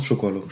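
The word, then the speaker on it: Šukalo: Aber Zuckerlo, Schukalo, wie spricht man ihn aus? Schukalo.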